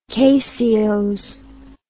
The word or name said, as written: caseose